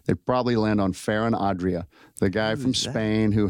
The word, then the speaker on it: Ferran Adrià: Ferran Adrià.